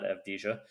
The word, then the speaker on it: Avdija: Just note on the pronunciation, there is no J sound in Hebrew. So it's Avdija, not Avdija. So Avdija is a savvy,